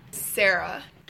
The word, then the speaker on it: Sarah: Sarah. – Can you spell your name? – So, my name is spelled S-A-R-A-H.